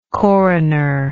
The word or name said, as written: coroner